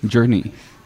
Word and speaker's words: Journey: Journey. Lo he dicho bien. – Journey. Ahí está.